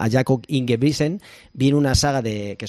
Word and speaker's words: Ingebrigtsen: Y para ello nos vamos a fijar en la figura de Jakob, a ver si lo digo bien, se dice Ingebrigtsen. – Ingebrigtsen, sí.